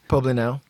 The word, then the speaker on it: Poblenou: So I'm going to start us off here in a neighborhood that's rattling my cage called Poblenou. – Poblenou. – Did I pronounce that right? – Poblenou.